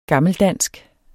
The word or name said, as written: gammeldansk